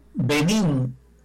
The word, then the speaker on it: Benín: Oiga, el Benín, así se dice con acento en la I, es un país de África Occidental.